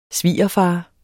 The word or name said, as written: svigerfar